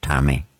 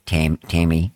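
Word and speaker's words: Tame: It's actually Tami, I think, isn't it? – Yes, Tami. You don't say Tami. Tammy, like you do.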